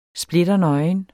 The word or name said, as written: splitternøgen